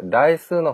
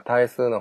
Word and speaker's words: 対数: まず注意ですけど、これ、代数の法則って読む人が多いですが、数学ではこれは対数の法則と言います。普通、対数って言うと、ログ、ね、ログの対数の方を思いますけど、そうじゃなくて、大きい数で、対数の法則。